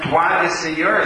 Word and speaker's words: droit du seigneur: I believe it is pronounced droit du Seigneur. Is that? Something like that. I realize I'm writing too small for some people to read. Droit du Seigneur. – Yes, thank you.